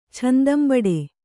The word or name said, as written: ಚೆಂದಂಬಡೆ